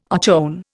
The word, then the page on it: ochone: Indian